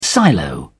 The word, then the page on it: silo: British English